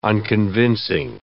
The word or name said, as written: unconvincing